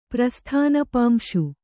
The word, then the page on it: ಪ್ರಸ್ಥಾನಪಾಂಶು: Kannada